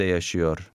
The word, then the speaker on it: yaşıyor: The initial Y in the last word is silent. – Teyzem Türkiye'de yaşıyor.